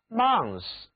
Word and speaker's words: month: one month，其中的 month 就是在词根 mon 后面加上名词后缀 th，字母 o 读作三角 r， month。